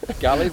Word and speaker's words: Gully: Gully rhymes with Scully.